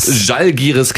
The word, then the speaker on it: Žalgiris: Gestern Abend gab es einen 69-62 Heimsieg gegen den litauischen Rekordmeister, wie spricht man es aus? – Žalgiris Kaunas.